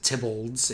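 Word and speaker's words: Theobalds: From his father, he inherited a country home called, it looks like Theobald's, it's Tybald's, it's usually pronounced.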